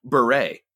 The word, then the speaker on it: Bure: Or as I've learned, her name is actually pronounced, at least this year, Bure.